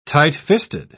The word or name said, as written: tightfisted